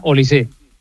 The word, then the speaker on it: Olise: No, es francés. Es no lleva acento, pero se pronuncia Olise.